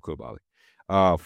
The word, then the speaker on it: Koulibaly: I think it's Koulibaly, French pronunciation, but we'll go with Koulibaly.